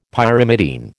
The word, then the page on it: Pyrimidine: American English